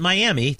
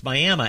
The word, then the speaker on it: Miami: Never pronounce it Miami. That's the way it's spelled, but it's Miami after a Native American tribe in Oklahoma, and had dinner on stage at the Coleman Theater, which is this – lots of towns have these.